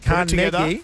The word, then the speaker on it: Carnegie: Hey guys, we've got more chances to win cash because this afternoon we are going to be at Carnegie. – No, you're butchering it again. – Damn it. – Come on, let's say, you ready? – Carnegie. We're going to be at Carnegie.